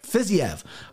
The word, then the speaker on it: Fiziev: Fiziev.